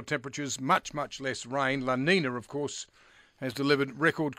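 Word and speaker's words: La Niña: La Nina and El Nino, I was told this morning.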